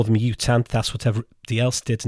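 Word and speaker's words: U Thant: But if you're being pernickety and look up the Wikipedia article about how to pronounce the former Secretary General's name, Ethant. Don't expect any research beyond this point. This is my research time finished. But Ethant, apparently, is how you pronounce the bloke's name.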